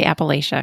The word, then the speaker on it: Appalachia: Am I saying it right? I want to say Appalachia because that's how I would say it. I know it's wrong. – Latch, like the latch on a door.